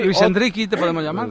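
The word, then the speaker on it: Endrick: Luego te vamos a preguntar por Endriki. – Sí, he escuchado a Melchor este mediodía que se dice así. – Endriki. – Y además su cuenta de Twitter acaba en i, así que hay que creérselo.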